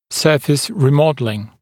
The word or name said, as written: surface remodelling